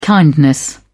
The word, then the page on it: kindness: British English